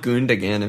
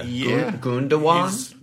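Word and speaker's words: Gündoğan: Like the second G is not a G. – What's that symbol on the. – Is he like a Star Wars character? – Yeah. – Gundogan? – Gundogan.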